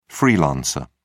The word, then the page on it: freelancer: British English